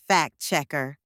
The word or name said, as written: fact-checker